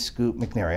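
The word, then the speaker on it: Scoot McNairy: Why is Scoop McNairy, I'm saying his name right? – Yeah.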